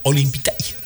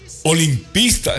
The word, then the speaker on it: Olimpista: Y me dice, Olimpita. No pronuncia lo bien, se porta. Olimpista es la palabra.